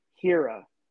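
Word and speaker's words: Hiura: So you pronounce his last name Hira, so it's Keston Hira.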